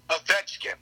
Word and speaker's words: Ovechkin: That's, yeah, Ovechkin. – Are you sure you're saying that right? – Yes. – Ovechkin.